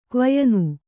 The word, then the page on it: ಕ್ವಯನು: Kannada